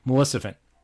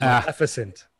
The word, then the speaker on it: Maleficent: Maleficent. I said it wrong again. – You said it wrong again. It's Maleficent.